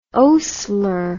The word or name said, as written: oastler